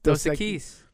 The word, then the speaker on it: Dos Equis: Is that, how do you say it? Dos Equis? – Dos Equis. Two X. Dos Equis.